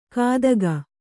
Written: ಕಾದಗ

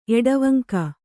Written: ಎಡವಂಕ

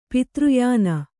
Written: ಪಿತೃಯಾನ

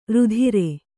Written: ರಾತ್ರೆ

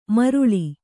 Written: ಮಾರುಳಿ

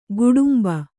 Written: ಗೋಡುಂಬ

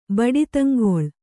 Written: ಬಡಿತಂಗೊಳ್

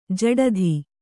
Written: ಜಡಿತ

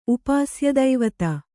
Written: ಉಪಾಸ್ಯದೈವತ

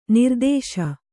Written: ನಿರ್ದೆಸೆ

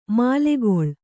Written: ಮಲ್ಲಗಳ

